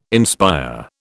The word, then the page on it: inspire: British English